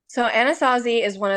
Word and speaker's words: Anasazi: So they said that their only choice after weighing all their options was to send him to the Anasazi, am I pronouncing this correctly? – Yeah, Anasazi camp.